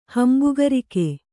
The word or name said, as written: ಹಂಬುಗರಿಕೆ